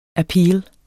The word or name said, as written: appeal